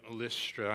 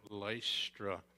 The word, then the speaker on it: Lystra: From Antioch, he made his way then to Iconium, and then from there to Lystra. I know a lot of people are prone to say in America, Lystra. But you see, once you get out of America, it's pronounced Lystra.